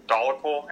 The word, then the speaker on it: dolichol: And this eventually gets turned into a molecule called dolichol. And how would you pronounce that? – Farnesyl-PP and dolichol. I think, yeah, dolichol. – Dolichol? Right. Okay, yeah, because it's a weird-looking word.